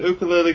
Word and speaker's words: ukulele: I'm pronouncing ukulele because that's how it's supposed to be pronounced.